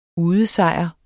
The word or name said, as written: udesejr